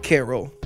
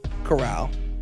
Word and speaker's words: Corral: Next question is about the Mississippi quarterback, Matt Carroll. Corral.